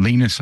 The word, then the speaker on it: Linus: And Linus was there, or Linus, I should say, because that's how he pronounces it.